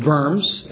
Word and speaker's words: Worms: Or Martin Luther at the Diet of Worms, that's how it's pronounced.